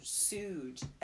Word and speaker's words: Suge: Yeah, I gave her, like, a whole ass background of Suge Knight. – Because my, like, American pronunciation, everything is to enunciate everything. So, Suge. S-U-G-E, Suge. But oh, no. Suge.